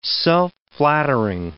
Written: self-flattering

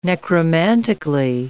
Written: necromantically

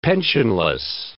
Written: pensionless

